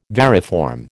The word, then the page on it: variform: American English